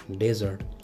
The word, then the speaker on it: dessert: D-E-S-E-R-T is pronounced as desert. On the other end, D-E-S-S-E-R-T is pronounced as desert.